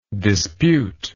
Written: disputer

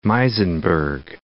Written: Muizenberg

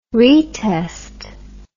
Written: retest

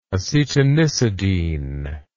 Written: acetanisidine

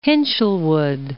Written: Hinshelwood

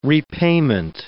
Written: repayment

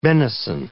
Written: benison

